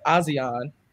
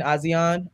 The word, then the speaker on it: Asiahn: Her name is pronounced Azion. But she has a new EP out called The Interlude, and the first song on it is called My World, and it's really good. So you spell it A-S-I-A-H-N, Azion.